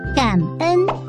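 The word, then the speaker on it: cảm ơn: 马卡西，越南语的多谢是读感恩，读起来甲国语的感恩真共款。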